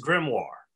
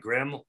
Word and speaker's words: Grimoire: So it's pronounced Grimoire. W-H-A-R. Like Grimoire. War.